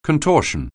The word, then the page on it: contortion: anglais